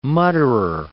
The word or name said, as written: mutterer